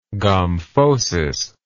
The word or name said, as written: gomphosis